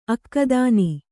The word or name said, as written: ಅಕ್ಕದಾನಿ